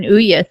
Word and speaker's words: Ouya: I also had to do a YouTube search on Ouya so I could pronounce it right.